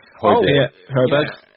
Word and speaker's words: Højbjerg: And I know today that we were linked with, I'm going to get the pronunciation wrong, but is it Hjuberg? – Hjuberg. Pierre-Emile Højbjerg.